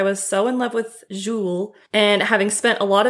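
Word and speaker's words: Julez: I believe it's a French name for the kid, J-U-L-E-Z. I think it's pronounced Jules.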